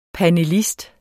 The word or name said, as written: panelist